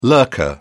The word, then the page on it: lurker: British English